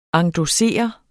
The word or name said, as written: endossere